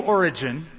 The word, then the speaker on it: Origen: From one source that we have, Origen, that's spelled O-R-I-G-E-N, Origen. And Origen, let me explain who he was one of the early church fathers from the second century.